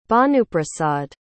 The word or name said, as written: bhanuprasad